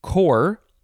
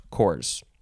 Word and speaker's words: corps: Next word is cor, but it is spelled C-O-R-P-S. It's a cor, but the plural is spelled the same way, C-O-R-P-S, but it would be pronounced cores.